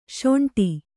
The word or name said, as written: ಶುಂಠಿ